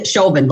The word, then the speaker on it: Chauvin: Derek Chauvin, how do you pronounce his last name? Chauvin, like chauvinistic.